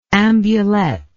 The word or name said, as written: ambulette